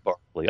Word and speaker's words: Berkeley: And then, of course, there's also we think of Bishop Berkeley, and his form of idealism, right. Sometimes immaterialism. – Spelled like Berkeley, but it's Berkeley. – Yes, it's pretty correct. It's pronounced Berkeley.